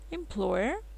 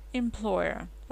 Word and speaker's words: employer: Employer. Работодатель. Здесь ударение уже на pl. Employer.